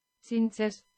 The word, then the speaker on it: Txintxes: Cinces. – Cinces. Cinces, which is chinchillas, according to Google Translate, from Basque to English, chinchillas. T-X-I-N-T-X-E-S.